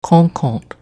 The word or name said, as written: Quonquont